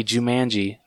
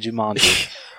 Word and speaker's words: Jumanji: Hey, I didn't say Jumanji. I actually said Jumanji. – Congratulations, you pronounced the word correctly.